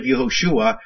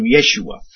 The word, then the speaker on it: Yeshua: The name is pronounced a little differently. So instead of Yehoshua, they're beginning to call him Yeshua or Yeshua.